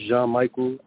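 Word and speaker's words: Jean-Michael: My name is Jean-Michel.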